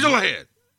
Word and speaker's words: Doohan: James Doohan. – And you're not even saying it right. It's Doohan, dude. You're not even saying his name right.